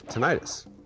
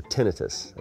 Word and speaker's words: tinnitus: Years of being stupid as a musician, not wearing earplugs, had led to me having really, really bad tinnitus, or tinnitus, as it was actually supposed to be pronounced.